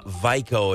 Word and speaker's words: Vajko: Again, Miriam Vico is how I'm going to pronounce it, V-A-J-K-O.